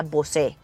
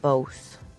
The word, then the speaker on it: Bose: Yo siempre le decía Bose. Hasta que un día alguien me dijo, con todo el cariño y el amor del mundo, no se dice Bose. Se dice Bose.